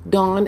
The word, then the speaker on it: Dawn: Dawn as in D as in dog,